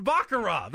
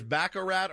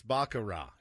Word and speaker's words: baccarat: Second of all, it is pronounced Baccarat. That's the way the word is pronounced. – We had a whole thing on it. I had to eat a lot of crow because I thought it was Baccarat or Baccarat, and it's not. It's Baccarat.